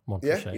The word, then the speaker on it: Montrachet: Bottled choices, Montrachet. And have I said that right? – Montrachet. Yeah.